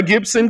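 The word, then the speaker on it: Gipson: So if you've been sending it to G-I-B, that's on you. It's all over everything we sent out. G-I-P, the Gibson Gazette.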